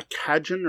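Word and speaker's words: cajón: For bigger games, I will recommend multiple times a Cajun or Cajun. I'm not even sure how it's pronounced. It's a type of drum. It's C-A-J-O-N. Look for a Cajun bag, which I think it's Cajon bag.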